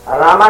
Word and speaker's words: राम: इसलिए रामा नहीं है राम है, इसलिए शुद्ध राम रूप में उच्चारण किया जाता है। नहीं तो रामा से पहुंच जाएंगे आप लक्ष्मी में, वैकुंठ में।